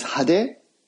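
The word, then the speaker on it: hade (派手): The word I'm thinking of is hade. We would spell it H-A-D-E.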